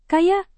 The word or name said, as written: kayak